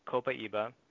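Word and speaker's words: Copaiba: So, if you've ever seen that word and you don't understand how you say it, it's Copa Iba.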